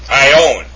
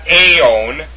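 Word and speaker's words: ion (αἰών): It's pronounced ion, actually, with the accent over the omega. Ion. It's not aeon, it's ion. Alright? You notice that the alpha iota is pronounced I. So it's ion. And you see the accent over the omega there. So it's not ion, it's ion. So the correct pronunciation of the form for age in the Koine is ion. It's not aeon, it's ion, okay?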